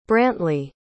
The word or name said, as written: brantlee